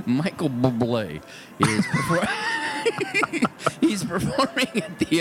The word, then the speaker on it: Bublé: Michael Bublé. – That's how you pronounce it. That's the proper way.